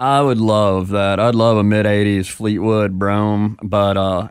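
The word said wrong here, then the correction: Brougham: Yeah, so you got to say it right, too. It's Broham.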